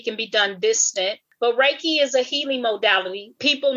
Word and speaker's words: Reiki: First of all, Reiki is a two-syllable word, which comes from two Japanese words, which means God's wisdom and life force energy.